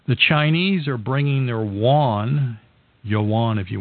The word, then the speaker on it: yuan: I always say J U A N. If you are Hispanic, you'll know how to pronounce it, but it's Y U A N.